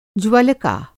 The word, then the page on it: ज्वलका: Hindi